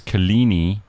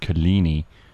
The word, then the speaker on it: Coligny: Apparently you pronounce Kalini, C-O-L-I-G-N-Y. You pronounce it Kalini, and that's in the northwest.